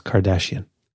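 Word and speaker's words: Kardashev: Can I take that again? – Yes. It's pronounced Kardashian.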